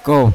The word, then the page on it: ko: Yawa